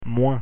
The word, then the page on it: moins: French